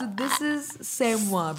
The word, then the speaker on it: c'est moi: I said it too, but it's French, so c'est moi.